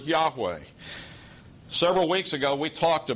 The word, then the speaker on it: Yahweh: It's the four consonants, YHWH, pronounced Yahweh.